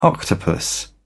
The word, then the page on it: octopus: British English